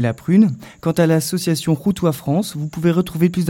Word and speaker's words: Khoutwa: Ça s'écrit K-H-O-U-T-W-A. Ça se prononce Routois, mais ça se dit K-H.